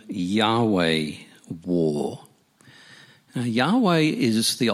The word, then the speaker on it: Yahweh: So when God reveals himself as I am who I am, that gets transliterated into the letters YHWH, and we pronounce that as Yahweh.